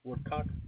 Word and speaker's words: Woodcock: Woodcock. – Could you spell that for me? – W-O-O-D-C-O-C-K.